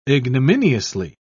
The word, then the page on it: ignominiously: British English